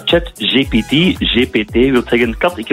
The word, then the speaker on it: ChatGPT: Nu heb ik het juist uitgesproken, hè? ChatGPT. – Uiteraard. – Ik zeg altijd ChatGTP. Ik weet niet hoe dat eigenlijk komt.